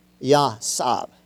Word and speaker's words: yatsab: Well, if you look it up in the Hebrew, that word is yasab. And it's spelled Y-A-T-S-A-B. But it's pronounced with a W sound, and they sort of drop the T.